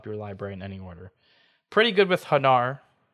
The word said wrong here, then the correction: Renar: It actually is Renar. I've been saying it wrong this whole time.